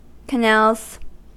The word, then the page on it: canals: American English